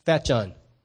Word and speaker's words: Fatjon: Fation is spelled like it's be pronounced... Fat John, F-A-T-J-O-N.